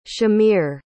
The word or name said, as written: shamir